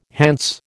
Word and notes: Hants: American English

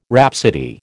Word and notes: rhapsody: American English

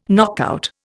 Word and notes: knockout: Indian

